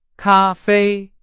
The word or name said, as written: 咖啡